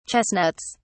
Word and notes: chestnuts: British English

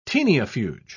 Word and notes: taeniafuge: British English

